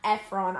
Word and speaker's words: Efron: I'm Zac Efron. I'm sorry. Every time I say his name, okay, I used to say when I was a kid it was Zac Efron, but it's Efron, and whenever I say them Efron.